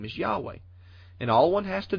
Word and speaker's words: Yahweh: And the pronunciation of these letters is debatable, but many Hebrew scholars, much more knowledgeable than myself, believe that these letters were originally pronounced something like Yahweh.